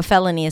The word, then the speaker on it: felony: So to pronounce it, you start with fe, that's the primary stress, an F sound, and the e as in red. Then le, an L in a schwa, fella, and then knee. Felony. Felony.